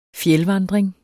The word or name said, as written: fjeldvandring